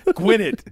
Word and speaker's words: Gwinnett: Gwinnett.